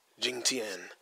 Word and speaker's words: Jing Tian: And Tom Hiddleston's character and Brie Larson's character and Corey Hawkins' character and Jiang Tian? Nope. Let me say her name right. Jing Tian's character are all going to be like old by like 40 years or something.